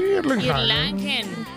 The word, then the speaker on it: Erlangen: En 1961 nace en Erlangen. Ay, Dios, ¿cómo se pronuncia? – Sí, Erlangen.